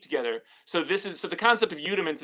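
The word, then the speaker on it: Youdiments: It's got the same name as my YouTube channel, Udiments. And what does that spell that so people can follow? – It's Y-O-U-D-I-M-E-N-T-S. So it's like rudiments.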